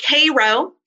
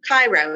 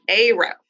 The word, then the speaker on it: Cairo: It's like the Egyptian city, pronounced Cairo. Oh. Cairo, Illinois. Cairo. Not Cairo, it's Cairo.